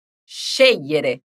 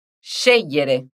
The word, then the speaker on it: scegliere: Diciamo tutto e fai attenzione alla tonica. Scegliere. Scegliere. Bravissimi!